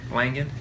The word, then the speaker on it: Langan: Langan, yeah.